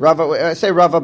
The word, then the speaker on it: Rava: Rabba, when pronounced is with a hay at the end, I say bays. And when it's Rava, I say with a vav. Okay, anyway. You know, pronounced like a vav.